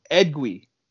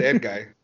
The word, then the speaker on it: Edguy: So I was corrected, and it's apparently pronounced Edgui, but it's spelled Edguy. – So I said Edguy.